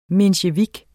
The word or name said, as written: mensjevik